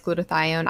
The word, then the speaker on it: glutathione: I generally do the Myers cocktail, but will also add glutathione.